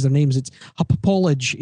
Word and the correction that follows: Hauppauge: H-A-U-P-P-A-U-G-E. Hupapauge. – Hupapauge. – Hupage. Yeah, sorry, Hupage.